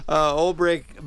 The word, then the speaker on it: Olbrich: And Old Brick, by the way, is spelled O-L-B-R-I-C-H. So it's like Old Bridge. – Yeah. – Rich. But it's pronounced Brick. Old Brick Botanical Gardens in Madison.